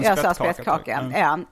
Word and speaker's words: spettkaka: jag tyckte väldigt mycket om det var spettkaka. – Ut, hur uttalade du det på den tiden? – Alltså eh. – den skånska uttalet är, har man hört är spiddekaga.